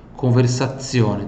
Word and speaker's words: conversazione: Conversazione, onde tem esse Z, tem som de TS, tá? Onde tem o Z aqui, tem som de TS. Até vou escrever aqui, ó. Ó. Conversazione, tá?